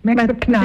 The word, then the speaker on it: Max McNown: Max McNown. – Het is niet Max McNown. – Max McNown. We leggen de klemtoon juist verkeerd.